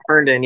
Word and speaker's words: Herndon: Herndon, yes.